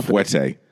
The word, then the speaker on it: fouetté: Until you've seen Ben Kingsley do a double fouetté.